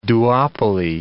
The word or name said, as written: duopoly